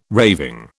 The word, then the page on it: raving: British English